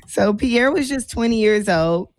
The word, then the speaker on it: Pierre: So first is the heartbreaking death of Perry Damas Bell. Does it sound like I'm pronouncing that correctly? – Pierre. – Pierre Bell. You say he Haitian, that's Pierre for sure.